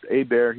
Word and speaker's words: Hebert: If they would do a web search for Family Caregiving, Warren Hebert, even though my last name is pronounced Hebert here in French Louisiana, it's spelled Hebert.